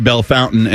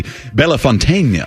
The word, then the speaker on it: Bellefontaine: I found a French guy who was saying how to pronounce Belle Fountain, and he said, yeah, in English you can say Belle Fountain, and he said, but the real French pronunciation should be Bellefontaine.